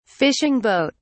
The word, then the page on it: fishing boat: British English